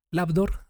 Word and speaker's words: Labdor: Labdor se escribe L-A-B de bueno, como de laboratorio, lab, y DOR de puerta en inglés, D-W-R, DOR.